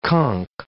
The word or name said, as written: conk